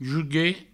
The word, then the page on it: juguet: Provençal